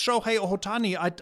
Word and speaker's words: Shohei Ohtani: And then also you have this, I think it's Shohei Ohtani. I don't know if I'm saying it right. I always read. – Yes. – Oh, okay, good.